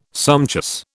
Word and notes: sumptuous: British English

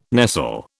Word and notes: nestle: British English

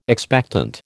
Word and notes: expectant: American English

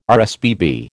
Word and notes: RSPB: American English

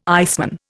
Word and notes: iceman: Indian

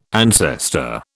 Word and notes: ancestor: British English

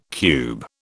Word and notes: cube: British English

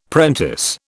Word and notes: prentice: British English

